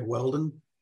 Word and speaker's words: Whelden: Just type in my name, Craig Whelden, W H E L D E N, not O N.